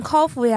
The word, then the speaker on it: 烤麸: 那也算的，像烤麸呀、扣三丝，还有白斩鸡这些东西都可以真空的。